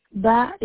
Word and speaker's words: Bah: First name is Isata, last name is Ba. – Can you spell that, please? – Yes, I see. A-I-S-S-A-T-A, Isata. Ba is B-A-H.